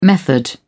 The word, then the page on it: method: British English